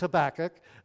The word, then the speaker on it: Habakkuk: Well, they don't pronounce the H in Portuguese. So this book is called Habakkuk in Portuguese. Just a fun word to say, right? Everybody say it, Habakkuk. It's just a fun word to say it. But in English, it's Habakkuk. Well, that's the way we pronounce it.